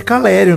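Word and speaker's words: Calleri: Ah, inclusive o Calari não jogou, né? Eu, eu só vi os melhores momentos, mas o Calari não jogou. É Caleri o nome dele. Gostei muito da sua pronúncia.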